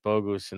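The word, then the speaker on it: Bogusz: Tielemann, Atuesta, Bogus in the midfield. What? Did I say that right? Yeah, I said that right. Bogus in the midfield.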